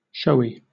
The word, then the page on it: showy: British English